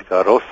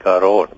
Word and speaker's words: Garros: So dit word as Garros uitgespreek en nie Garon byvoorbeeld nie.